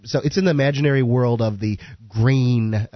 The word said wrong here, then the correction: Grania: Grania is the Celtic pronunciation.